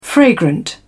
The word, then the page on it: fragrant: British English